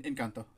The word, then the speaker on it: Encanto: Is it Enchanto? Encanto? Am I am I pronouncing Enca- this wrong? No, in, in, in, Encanto.